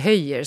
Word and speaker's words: Höller: Hans efternamn stavas ju med två L, alltså Höller. Men man uttalar det Höjer, som jag har förstått det i alla fall.